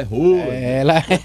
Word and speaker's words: Haaland: Mas a pronúncia é diferente. – Foi muito. É Haaland.